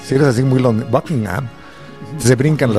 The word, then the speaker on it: Buckingham: Es Buckingham. – Eso y de hecho, inglés perfecto, el H medio que se es medio silencio. Buckingham. – Más House. – O menos. – No se pronuncia mucho.